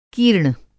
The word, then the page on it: कीर्ण: Hindi